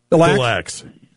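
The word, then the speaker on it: Des Lacs: How do you say it again? – The Lax.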